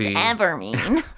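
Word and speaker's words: Evamene: So that is it's Evermean. Say it right. It's Evermean.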